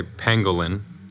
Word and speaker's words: pangolin: I want to say pangolin.